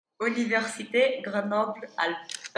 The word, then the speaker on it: Université Grenoble-Alpes: Université Grenoble-Alpes.